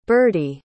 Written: birdy

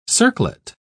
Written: circlet

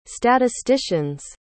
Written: statistician's

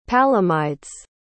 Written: palomydes

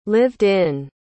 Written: lived-in

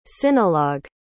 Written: sinologue

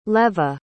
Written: leva

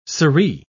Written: sirree

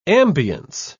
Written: ambiance

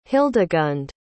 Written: hildegund

